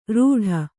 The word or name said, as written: ರೂಢ